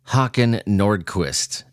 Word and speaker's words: Hakan Nordkvist: Hakan Nordkvist is how you say it.